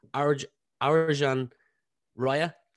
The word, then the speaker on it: Arjan Raya: to be joined by Arjan Raya. Did I pronounce that correct? – Arjan Raya, like Argentina without the Tina.